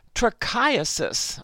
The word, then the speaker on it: trichiasis: The next, a term I hadn't heard of, trichiasis. I'll spell it for you, T-R-I-C-H-I-A-S-I-S.